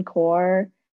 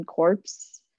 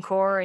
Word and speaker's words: Corps: Corps. But at first he was pronouncing it like Marine Corpse.